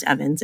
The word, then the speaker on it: Ewens: It looks like Ewens. It's Stephanie E-W-E-N-S. But it's pronounced Evans. It's weird, I know.